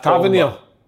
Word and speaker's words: Tavernier: Tavernier.